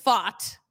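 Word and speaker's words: Pfaadt: So I'm looking at the DraftKings Sportsbook app right now, and Brandon Fott, that is how you say his name, is on the mound tonight.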